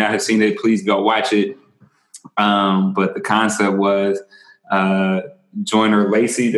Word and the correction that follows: Lucas: Did I say that right, Kasan? No, Joyner Lucas. My bad, Joyner Lucas.